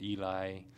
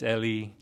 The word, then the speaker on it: Ely: My first name is spelled E-L-Y, and then people would pronounce it Eli. And sometimes I'd be like, okay, I'm not gonna fix them. They're just gonna keep calling me Eli. So now it's like, all right, it's Ellie, but you could call me Cap.